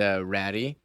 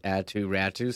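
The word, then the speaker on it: Aatu Räty: It's not pronounced Ratty. It's Atu Ratu.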